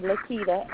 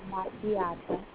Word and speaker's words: Licita: I'm on Facebook at my real birth name, Lakita, L-I-C-I-T-A. I pronounce it Lakita. L-I-C-I-T-A, Sweet Baby Keita Cromer.